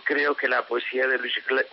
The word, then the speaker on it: Glück: Pues Louise Glück, y permítame que lo pronuncie en su pronunciación alemana, porque Glück es además, no sé si ustedes lo saben, significa en alemán suerte.